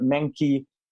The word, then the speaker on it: Menke: I am joined by Tyler Menke.